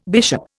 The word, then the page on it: bishop: Indian